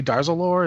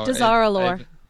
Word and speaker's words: Dazar'alor: I want to say Darzalore. Is that how you pronounce that? – Darzalore. I don't know. – Dazar'alor.